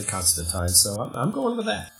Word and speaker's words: Constantine: Oh, I think even John Constantine is in there, and I'm going to say Constantine's name probably 200 to 300 times an episode because that's how it's pronounced. Not teen, but time.